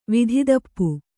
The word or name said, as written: ವಿಧಿದಪ್ಪು